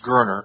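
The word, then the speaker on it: Goerner: The best way to pronounce his name is Gerner. – As if it's an E. – It's Gerner. Fred Gerner. And yes, we're skipping one step.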